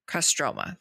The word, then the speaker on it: Kostroma: And during the festival, there is a straw figure that portrays Kostromina. Why did I forget how to say this? Kostroma. There we go. I was like, storm. Kostroma. So the straw figure portrays Kostroma.